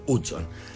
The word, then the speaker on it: Hudson: Bien, sí, no Hudson, no Hudson, Hudson.